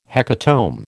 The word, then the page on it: hecatomb: American English